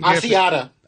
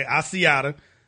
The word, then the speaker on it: Asiata: Asaicia? I don't know how to pronounce it. Dude had 13. – Asiata. – Okay, Asiata.